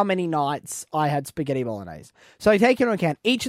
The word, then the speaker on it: bolognese: You don't pronounce the G. – But you can't, it's kind of there, isn't it? Bolognese. Is that better? – Yeah.